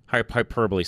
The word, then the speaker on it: hyperbole: There's as much hyperboil that goes on our side of the fence as there is, or how do you say that, Charlie? Hyperbole, sorry, I need to say that right.